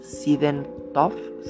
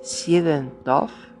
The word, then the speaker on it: Siedentopf: Sidentoff, Se, se escribe S-I-E-D-E-N-T-O-P-F. Sidentoff, que redujo toda la actividad del universo a un solo año.